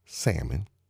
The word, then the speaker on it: salmon: Don't let your people pronounce the L in salmon.